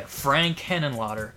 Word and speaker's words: Henenlotter: Frank Henenlotter. Is that how you say his name? – Yeah, Frank Henenlotter.